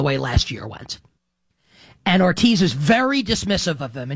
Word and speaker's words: Hortiz: There is an exchange after the season, I believe, at some point this summer maybe, where it's a Joe Ortiz, it's his name is spelled H-O-R-T-I-Z, and I believe it's pronounced Ortiz, like the H is silent, perhaps.